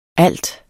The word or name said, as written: alt